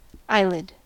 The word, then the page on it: eyelid: American English